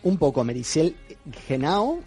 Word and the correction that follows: Meritxell: Lo has dicho fatal. – Muy mal. – Se dice Meritxell. – ¿Vale? – Merichel.